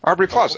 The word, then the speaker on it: Aubrey Plaza: Aubrey Plaza.